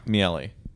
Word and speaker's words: Miele: How do you pronounce this brand's name? – Miele.